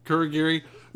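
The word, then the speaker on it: Kurogiri: I can't pronounce his Kurigiri.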